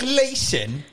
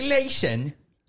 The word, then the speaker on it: Leeson: Yeah, but he's British, so please, you have to pronounce his name right. It's Nick Leeson. – Nick Leeson. – It's Nick Leeson.